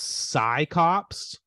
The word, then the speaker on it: Psi Cops: But is it pronounced Psi Cops? – Oh, you got it.